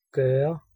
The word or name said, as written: perform